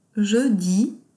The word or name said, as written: Je dis